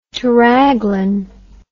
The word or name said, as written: teraglin